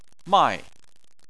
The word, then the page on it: mai: 4